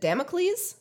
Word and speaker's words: Damocles: Is it Damocles? Is that how we pronounce it? I only ever read it, and I'm that not. Sounds right. – Sounds yeah, I think yeah.